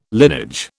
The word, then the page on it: linage: British English